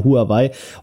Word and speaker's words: Huawei: Und zwar reden wir über Huawei, je nachdem, wie wir es aussprechen. Wir haben ja die deutsche Kartoffelversion hier mit dabei. Wir sagen ja Huawei.